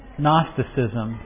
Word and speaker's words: Gnosticism: Gnosticism. G-N-O-S-T-I-C-I-S-M. Gnostic. It's like Gnostic. Gnosticism is how you'd hear it, how you'd look at it. Let me get my little pen. You don't pronounce the G, basically, but here's how it's spelled, just so you get an idea.